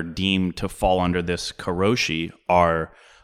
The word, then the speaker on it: karoshi: It's just karoshi, K-A-R-O-S-H-I.